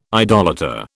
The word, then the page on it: idolater: British English